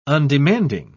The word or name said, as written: undemanding